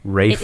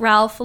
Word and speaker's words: Ralph: Rafe. – It isn't Ralph like Ralph Wiggum, but Ralph like Rafe or She says R-A-F-E or R-A-Y-P-H.